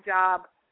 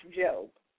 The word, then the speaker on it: Job: It looks like Job but it's pronounced Job.